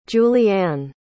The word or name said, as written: juliann